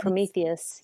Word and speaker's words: Promethease: Um, I did um, also upload it to, and I don't know that I know how to pronounce this right, but Prometheus Oh, yes. Promethease. Yeah. Thank you.